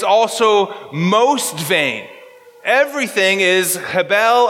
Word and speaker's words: hebel: It's hebel. Hebel. And it means hebel or hevel, if you like it that way, in the Germanic kind of pronunciation.